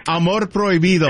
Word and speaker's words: Amor Prohibido: Amar Prohibit. Habibia. – Amar Prohibit. All right. – I don't know how you say that. – How would you say it? – Amor Prohibido. – Yes.